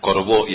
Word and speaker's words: Corbo: Mientras decís el número, sigan a Corbo Wine Bar en Instagram, con B alta, Corbo y acento en la segunda O.